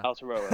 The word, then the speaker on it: Aotearoa: Aotearoa, yeah.